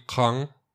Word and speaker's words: Caen: sono stato in Normandia nel 2018 ed effettivamente, a parte il memoriale di, non me ne vogliono i francesi, Caen, Caen per quelli che come Caen. me non sapevano la pronuncia corretta.